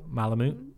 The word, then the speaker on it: Malamute: The Alaskan, is it pronounced Malamute? – Malamute.